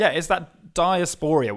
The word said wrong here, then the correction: diaspora: which is one of the... Is that the right way of pronouncing that? Diaspora.